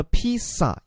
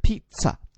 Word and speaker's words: pizza: 其实它发音的时候，大家注意，它那个 z 啊，大家其实不要把它发成了 pizza，也不要把它发成 pizza，而要发成 pizza。这个 z 发的是这样的一个音，所以叫做 pizza。